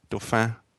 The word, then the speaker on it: Dauphin: Probably have to kind of like nasal the second syllable of something like Dauphin or something to that effect. Dauphin.